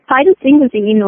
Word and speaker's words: phytosphingosine: Yes, phytosphingosine, or for obvious reasons, we oftentimes refer to it as simple PS.